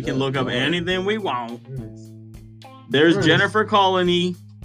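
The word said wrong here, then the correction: Connelly: Is that how you say it? Colony. Connolly. – Connolly.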